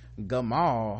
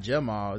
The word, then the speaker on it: Gamal: Gamal. I've also never seen that. It's not Jamal. It's G. It's G A M A L. Maybe it's pronounced Jamal, even though Probably. it's Gamal.